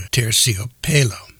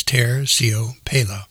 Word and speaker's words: Terciopelo: And the name of the snake is called a Terciopelo. I know I butchered that, but let me try it again. It's Terciopelo. It's also known as the fur de lance.